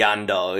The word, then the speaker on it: Andal: Jorah the Andal? Is that how you say it? The Andal, yeah.